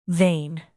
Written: vain

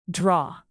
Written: draw

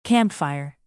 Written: campfire

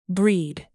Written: breed

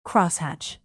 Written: crosshatch

